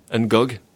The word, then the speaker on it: N'Gog: How do you pronounce it? – N'Gog.